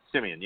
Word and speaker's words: Siemian: Siemian, yep.